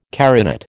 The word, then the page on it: carinate: American English